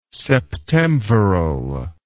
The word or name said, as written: septemviral